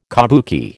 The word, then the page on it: kabuki: American English